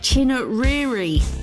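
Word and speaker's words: Chinouriri: I did actually just say her name wrong then, didn't I? It's Rachel Chinnariri.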